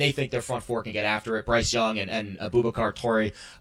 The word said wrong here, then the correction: Traore: Traore, I always mispronounce his name.